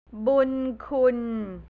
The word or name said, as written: bunkhun